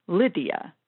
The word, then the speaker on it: Lydia: Lyd-E-A. The stress is actually on the first syllable, Lyd, Lydia.